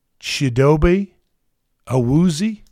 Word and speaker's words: Chidobe Awuzie: He brought in Chidobe Awuzie, if I'm pronouncing that correctly. – I believe that's correct.